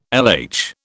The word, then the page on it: LH: British English